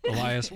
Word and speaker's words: Elias: I feel like it should be Alias, but it's spelled with an E. – Is it Elias? – I don't know. – E-L-I-A-S? – Like, yes. Eli? – That's Elias.